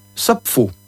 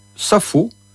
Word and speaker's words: Sappho: Il faut donc prononcer Sappho et non Sappho, comme on a coutume d'entendre.